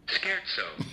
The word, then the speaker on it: scherzo: Scherzo. Not to be confused with schizo.